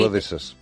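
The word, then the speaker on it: Abadesses: Abades, provincia de Gerona. Siempre que nos vamos de viaje se repite... Abadesas. – De los abadeses, perdón, provincia de Gerona.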